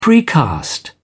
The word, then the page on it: precast: British English